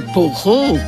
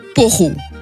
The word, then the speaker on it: poireau: Le mot s'écrit bien P-O-I-R-E-A-U et la prononciation attendue est donc poireau. Mais peut-être que vous dites quand même poreau et que vous êtes tenté d'écrire poreau éventuellement avec deux R pour bien montrer la prononciation neuchâteloise. Dans les dictionnaires actuels, on trouve seulement l'entrée poireau, donc P-O-I-R-E-A-U,